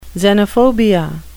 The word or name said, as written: xenophobia